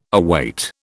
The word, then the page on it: await: British English